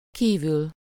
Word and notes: kívül: /ˈkiːvyl/

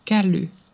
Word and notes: käly: /'kæ.ly/

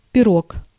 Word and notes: пирог: /pʲɪˈrok/; pirog

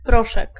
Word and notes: poudre: France; [pudʁ]

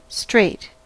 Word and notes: strait: American English; [ˈstreɪt]

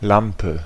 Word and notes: Lampe: /ˈlampə/

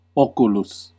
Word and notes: bud: /ˈbʌd/; [ˈbʌd]

